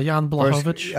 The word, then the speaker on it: Blachowicz: Blachowicz versus Clark. Am I saying the name right? Are you going to scream at me? – No, that was right. Yeah, Jan Blachowicz.